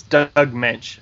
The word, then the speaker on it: Moench: it's Doug Moench. That's how it's been pronounced before.